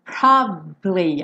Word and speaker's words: probably: Probably é uma palavra que tem três sílabas, só que essa sílaba do meio, ela é bem discreta, ela é bem escondidinha, tá bom?